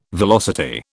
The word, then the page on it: velocity: British English